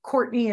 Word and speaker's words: Kortnee: I am Kortnee at Confluence Healing Arts. And as you may have noticed, my name is spelled differently. Kortnee is Kortnee.